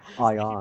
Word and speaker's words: Ian: Ion. – He pronounces it Ion.